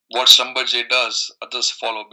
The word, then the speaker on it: Schlumberger: Yeah, so the company, so it is pronounced Schlumberger.